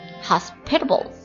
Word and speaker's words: hospitable: 那今天咱们要学的呢这个单词长得有点像医院那个单词 hospital，它叫做 hospitable，或者两种读音，或者是 hospitable，我倾向于后一种 hospitable。